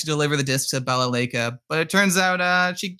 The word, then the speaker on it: Balalaika: Was it Balalaika? – Balalaika, that's how you do it.